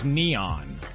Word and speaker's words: N3on: I think that you don't know because his name is Neon. – No, it's spelled N, the number 3-O-N, N3on. – Yeah, but he goes by Neon.